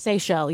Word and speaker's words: Seychelles: Seychelles, yeah. – The Seychelles? – Yeah. – The island, islands? – S-E-Y-C-H-E-L-L-E.